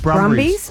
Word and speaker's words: Brumbies: Them and Bunburys. Is that how you say it? – Brumbies.